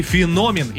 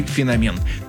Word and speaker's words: феномен: Ну и феномен. Именно так следует произносить это слово, хотя некоторые версии допускают иногда и феномен.